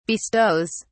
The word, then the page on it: bestows: British English